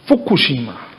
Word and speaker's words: Fukushima: Convido a doutora Márcia Mayumi Fukushima, médica neurologista e diretora do AME Idoso Sudeste. Eu falei errado? – Fukushima.